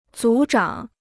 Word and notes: 组长: zǔ zhǎng

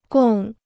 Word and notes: 共: gòng

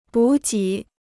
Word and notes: 补给: bǔ jǐ

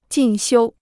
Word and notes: 进修: jìn xiū

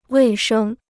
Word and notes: 卫生: wèi shēng

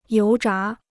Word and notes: 油炸: yóu zhá

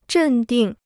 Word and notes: 镇定: zhèn dìng

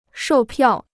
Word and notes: 售票: shòu piào

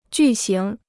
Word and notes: 巨型: jù xíng

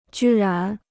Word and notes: 居然: jū rán

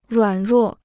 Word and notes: 软弱: ruǎn ruò

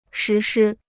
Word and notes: 实施: shí shī

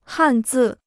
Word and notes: 汉字: hàn zì

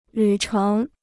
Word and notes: 旅程: lǚ chéng

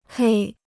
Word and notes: 黑: hēi

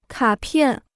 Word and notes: 卡片: kǎ piàn

